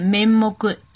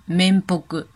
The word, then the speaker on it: 面目: 其实这个词是有两种发音的。既可以念 “memog”，也可以念 “membog”。